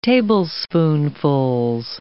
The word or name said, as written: tablespoonfuls